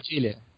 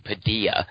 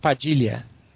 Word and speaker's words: Padilha: His name is Jose Padilla. So your director's name is not pronounced that way. Is that correct? – Yeah, it's Padilha.